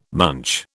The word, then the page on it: munch: British English